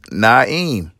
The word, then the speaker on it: Naim: But to me, it is Naim. Easy. N-A-I-M. So if you call me Name, first of all, who's going to name their child Name? – Bruh. – Name Ali? No, it's Naim.